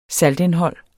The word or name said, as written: saltindhold